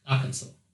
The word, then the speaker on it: Arkansas: Nah, it hasn't got an L. – No, I think it's pronounced Arkansas. – But where does the L come from? Arkansas. No, not Arkansas. Arkansas, like with the W.